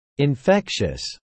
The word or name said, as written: infectious